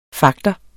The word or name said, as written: fagter